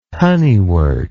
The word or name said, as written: honeywort